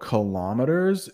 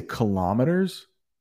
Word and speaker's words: kilometers: Well, one thing about kilometers is that we have two pronunciations in English. So I say kilometers, but you will hear some say kilometers. And I think both are accepted. Kilometers, kilometers. So take your pick.